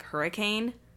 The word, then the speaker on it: Hurricane: There's a city called Hurricane and it is spelled exactly like hurricane, but it's not hurricane. It's Hurricane. Or there's another city not too far from Salt Lake called Tooele that is spelled T-O-O-E-L-E.